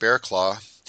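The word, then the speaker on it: Barraclough: Well, the last name that I have on the list is probably one of the coolest names to say because it's literally pronounced Bearclaw.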